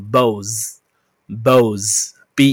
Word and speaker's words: beaux: So B E A U bow 就是情郎，它的复数比较特别，一不发音。 OK 好，So 叫做 bows，bows，B E A U X，B E A U X，bows，这叫情郎哦。